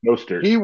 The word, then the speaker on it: Mostert: Mostert.